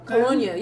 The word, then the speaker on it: Colonia: Which, speaking of pronunciation, is that Colonia? – Colonia, yep.